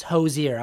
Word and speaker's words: Hozier: We were talking about that someone said his name is Hozier. That's how you pronounce it. It's Hozier.